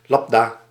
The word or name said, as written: lambda